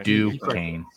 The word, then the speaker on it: Duquesne: How do you actually pronounce it though? Because we've been saying it wrong so long that it's Duquesne. Okay. – Yeah, Duquesne.